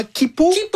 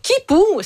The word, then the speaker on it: Quipu: Et si vous pensiez que c'était déjà vertigineux d'imaginer l'année Akea, attendez qu'on vous parle de comment Kipu. Ça s'écrit Q-U-I-P-U. – Oui. Donc, Kipu, c'est deux fois plus grand que l'année Akea.